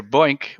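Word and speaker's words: BOINC: So just to get things started before we get into the details here, Boink, which is a word I've said many times now, is an acronym for the Berkeley Open Infrastructure for Networked Computing.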